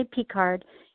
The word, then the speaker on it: Picard: Is it Picard or Picard?" I say, "Picard."